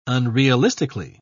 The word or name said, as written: unrealistically